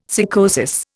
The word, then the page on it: sycosis: Indian